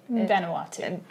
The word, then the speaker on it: Vanuatu: Vanuatu. – In Vanuatu.